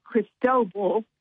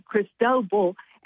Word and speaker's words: Cristobal: But then next for the seas, we would have, I think we pronounce it Cristobal. Kind of looks like Cristobal. – Right. – But his name would be Cristobal.